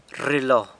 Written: rrilo

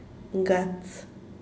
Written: ngats

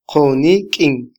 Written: qonikyin

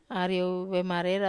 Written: ariou vemarera

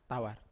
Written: tavar

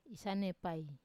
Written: isanepai

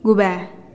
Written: gubee

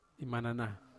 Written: manana

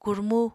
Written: kurmu

